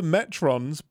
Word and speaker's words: Metrons: I was writing in like Metrolos? – Metrolos? No, it's the Metrons, but and that's clearly because that is put in after the fact.